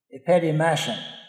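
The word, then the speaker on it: epitimasen: The word there in Greek actually is, I'm going to try and pronounce it, I'm not good at Greek. It's E-P-I-T-I-M-A-S-E-N, epitimason.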